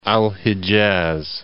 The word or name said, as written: Al Hijaz